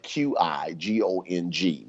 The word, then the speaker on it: Qigong: It's pronounced you spell it Q I G O N G, but you pronounce it Qigong. Q I is Qi in Chinese, meaning energy.